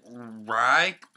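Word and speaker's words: Wry: I pronounce it Rye, but it's W-R-Y.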